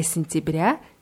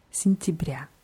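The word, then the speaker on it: сентября: Bei Sintibria ist die Betonung auf dem letzten Jahr, deswegen werden die ersten beiden Vokale eher wie Is ausgesprochen. Sintibria.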